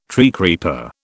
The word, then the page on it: treecreeper: British English